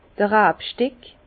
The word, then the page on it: d'r Rabsteck: Bas-Rhin; Herrlisheim